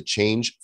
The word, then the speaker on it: Chainge: Download the Chainge Finance app today at Chainge dot finance android or ios it's spelled c-h-a-i-n-g-e it's like chain and change had a little baby and they became chainge dot finance